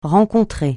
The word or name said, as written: rencontrer